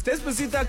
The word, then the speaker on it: Despacito: Because you're mispronouncing. – No, that's how you say it. It's Despacito, Calandito, Mandatito.